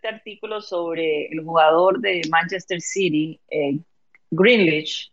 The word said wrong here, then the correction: Grealish: ¿verdad? es como se pronuncia. Jack Grealish, sí.